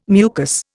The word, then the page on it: mucus: Indian